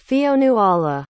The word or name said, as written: Fionnuala